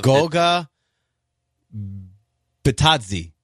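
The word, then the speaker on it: Goga Bitadze: Goga Batadze. Batadze. – That's the player, right? Okay, I'm going to give you that one. That's close enough.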